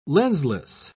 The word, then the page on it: lensless: British English